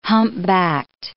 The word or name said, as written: humpbacked